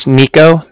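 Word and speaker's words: Niko: So my name's Nico. That's N-I-K-O. I'm calling with the Bank of Cardiff, and that's spelled C-A-R-D-I-F-F, as in Frank Frank.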